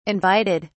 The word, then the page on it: invited: British English